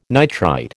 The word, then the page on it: nitrite: American English